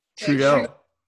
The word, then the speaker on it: Trudeau: Trudeau? – Trudeau.